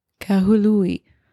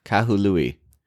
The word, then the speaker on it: Kahului: Kahului. – Kahului.